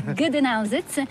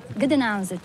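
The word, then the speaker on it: Gdańsk: Gdansk, c'est le nom slave. Gdansk.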